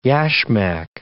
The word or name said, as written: yasmak